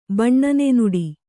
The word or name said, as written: ಬಣ್ಣನೆನುಡಿ